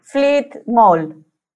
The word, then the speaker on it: Fleet Maul: Fleet Mall. Se escribe Fleet Maul.